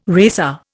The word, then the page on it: racer: Indian